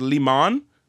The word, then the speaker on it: Liman: Number one, Doug, I'm pretty sure his last name is Liman. That's how you pronounce it.